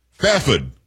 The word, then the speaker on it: Fairford: Fafid is what I was told how I should pronounce it from Kay.